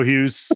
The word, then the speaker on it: Houghes: I'm Joe Houghes.